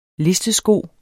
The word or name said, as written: listesko